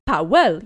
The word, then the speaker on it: Powell: And he says it as, I can't remember his first name. Powell. My name is something Powell. – Powell, yeah, that's how you say it if you...